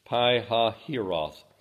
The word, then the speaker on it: Pi-hahiroth: Speak unto the children of Israel, that they turn and encamp before Phi-Hahiroth. I know the first time I looked at that, I looked at and up and know how to pronounce it. Pi-Hahiroth. Between Migdal and the sea, over against Baal Ziphon.